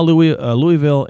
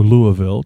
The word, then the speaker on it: Louisville: But Omaha, Louisville, or Louisville, depending on how you say it, Kansas City, $43,000 to $47,000 a year.